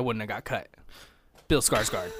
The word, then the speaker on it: Skarsgård: Skarsgård. – Skarsgårdy? – Like a scar, like a, I'm cut, now it healed, I have a scar.